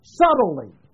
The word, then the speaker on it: subtly: They show you how it's pronounced. It's S-U. Tilly. Subtly is how it's pronounced. The B is silent. But the S-U-B is a prefix.